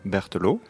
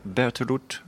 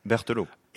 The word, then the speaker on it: Berteloot: I'm Mickaël Berteloot and not Berteloot or something else.